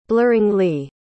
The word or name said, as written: blurringly